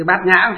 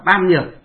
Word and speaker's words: bát nhã: Nhà Phật thì đọc là nhã. Bát nhã có nghĩa là trí tuệ, ở ngoài đời họ đọc là ban nhược.